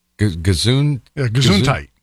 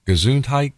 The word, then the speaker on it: Gesundheit: Gesundheit. Gesundheit? – Yeah, tight. – Tight? – Yeah.